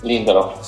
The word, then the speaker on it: Lindelof: Lindelof.